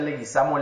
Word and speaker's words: Leguizamo: Se, pronuncia Leguizamo Leguizamo.